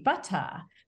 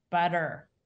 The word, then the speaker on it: butter: That T that's pronounced like a D between a stressed and unstressed syllable is something that only developed in the United States after most of the colonists had settled here. So British speakers either glottalize, so ba-a, or they pronounce the T, butta. But an American says butter.